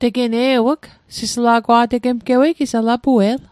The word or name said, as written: sisla'gwa'tegemgewei